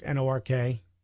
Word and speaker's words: Newark: I pronounce it Newark, Nork. That's how you pronounce it when you're from there. – You and Gordon Baker Bone. – Yeah, because when you're from there, that's how you pronounce it, Nork.